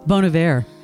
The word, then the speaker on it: Bon Iver: I thought it was Bon Iver. – Bon Iver. – Is that how you say it? – Yeah.